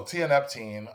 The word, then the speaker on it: tianeptine: One example is thioneptine, if I pronounce that correctly. What is that, and where is that found? – So thioneptine, T-I-A-N-E-P-T-I-N-E.